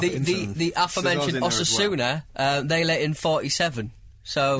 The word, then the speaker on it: Osasuna: Osasuna.